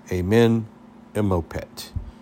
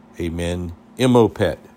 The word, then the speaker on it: Amenemopet: The instruction of Amen, like Amen, M-O-P-E-T. Amenemopet. Amenemopet.